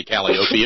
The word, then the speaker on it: Calliope: Blame Anderson Cooper. They pay him millions of dollars a year to say Calliope. It must be the correct pronunciation.